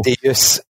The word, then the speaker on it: Deus: tu prononces ça comme il faut. Deus, c'est latin. C'est Deus.